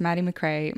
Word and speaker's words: Maddy MacRae: My name is Maddy MacRae.